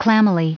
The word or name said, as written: clammily